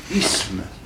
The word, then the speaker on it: isme: Mais la prononciation correcte, c'est « isme ».